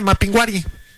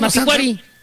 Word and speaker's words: Mapinguari: Sí, nada más que lo leyó mal. Ahí dice Mapinguari. – Dice Mapinguari. Ah, es que no tiene acento en la I. – No tiene acento en la I. – Mapinguari.